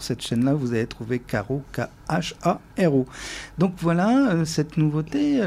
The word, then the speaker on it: Kharo: c'est le groupe Caro qui s'appelle Caro, mais que ça s'écrit K-H-A-R-O, avec peut-être de petites choses en plus,